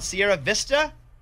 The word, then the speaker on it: Sierra Vista: Hey, how do you say your town? Sierra Vista? – Sierra Vista. – Yeah, I was going Vista, but Yeah. – Maybe two Spanish.